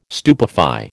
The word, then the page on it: stupefy: American English